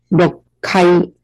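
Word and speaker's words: 六階: 然后呢，再来呢，就是六呢，也不念成了 loku 了，它念成了 lo，有一个小促音的部分 lokai。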